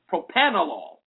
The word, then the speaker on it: propranolol: I have to make sure I'm saying that right. P-R-O-P-R-A-N-O-L-O-L. Propanolol. Apparently, it reduces implicit negative racial bias.